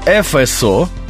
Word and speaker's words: ФСО: Достаточно новые аббревиатуры, и в них э, произносится буква F так, как она произносится в литературном языке. F. – Я всегда слышал ФСО как раз. – Но вот надо говорить ФСО.